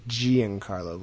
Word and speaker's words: Giancarlo: It's probably a soft G, Giancarlo Volpe, who seems to get some waterbending episodes a lot of the time, and strong women episodes, too.